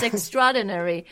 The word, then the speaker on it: extraordinary: It's extraordinary. 所以呢,发音你也要特别注意哦。你不要发成 it's extraordinary. 你要把它连起来。 – Extraordinary. – Extraordinary, extraordinary. 有点像你怎么发 strawberry, 草莓对吧 ,strawberry, 然后这个是 extraordinary.